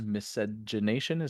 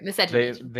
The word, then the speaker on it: miscegenation: They are against miscegenation. Is that the correct way to say that? – Yeah, I don't know where you're getting this from, but please explain. Yeah, you said it right. Miscegenation.